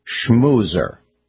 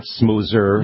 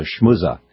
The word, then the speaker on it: schmoozer: Is this the proper pronunciation of the word schmoozer? Schmoozer. Schmoozer. – That sounds good. – Not smoozer. – No. – Or if you come from Boston, it's a schmooza.